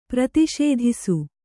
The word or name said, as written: ಪ್ರತಿಷೇಧಿಸು